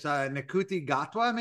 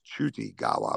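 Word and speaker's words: Ncuti Gatwa: I couldn't pronounce his name, but it's Nkuti Gatwa, maybe, or something like that. – Yeah, I think, yeah, that's what it is. I think the first name is actually pronounced Chuti Gawa or something like that.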